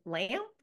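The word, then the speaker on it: Lampe: Her name is Lucy Lamp. Lamp, I think is how you pronounce her last name. It's L-A-M-P-E.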